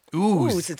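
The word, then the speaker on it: Uzès: We are from Lossiemouth, but listening to you tonight from Ouzes. Ouzes, have I said that correctly? Ouzes. – Ouzes? – In the south of France. – Ooze. – Ooze. – Probably the S's sound.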